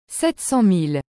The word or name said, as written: 700000